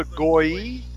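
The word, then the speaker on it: goy: The people, the Goi, is like Goi, which means nation in Hebrew. – Right, yeah. – Since in northern dialects, Sabalin and Zebulon would have been alternate pronunciations of the same name. The Z was replaced by a capital S, and Goi means people in Hebrew. It's G-O-Y, Goi.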